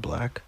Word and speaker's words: 6LACK: That's 6LACK, but I think he pronounces it Black. I'm pretty sure the 6 just signifies his residence.